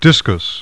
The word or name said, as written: DISCUS